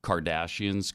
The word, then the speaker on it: Kardashian: I couldn't care less about the Cardassians. Kardashians, Kardashians. What have I said? – Mayonnaise. – No, what is it? – You pronounce their last name Cardassian, and it's actually Kardashian with an H.